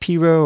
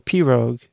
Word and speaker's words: pirogue: It's pronounced piro or pirogue, and it's spelled P-I-R-O-G-U-E.